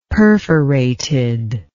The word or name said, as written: perforated